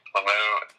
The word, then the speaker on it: Lemieux: Lemieux, of course, is one of the greatest of all time.